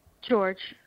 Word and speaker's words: George: George, like the man's name.